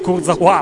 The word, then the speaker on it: Kurzawa: Adesso è Kurzawa la pronuncia, tanto ormai entra Dumbia, la partita da seguire diventa per forza quella della Juve.